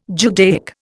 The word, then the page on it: Judaic: Indian